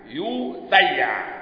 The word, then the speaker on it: يسيع: حديث عبد الرزاق عن الثوري عن أبي إسحاق عن زيد ابن يسيع بضم الياء وفتح الياء التحتانية، تمام؟ وفتح الساء وتسكين الياء، يسيع تمام يا اخوانا؟ تنطق هكذا يسيع، بضم الياء التحتية المثنى وفتح الساء المثلثة وإسكان الياء التحتية المثنى وآخره عين مهملة،